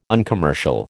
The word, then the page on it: uncommercial: American English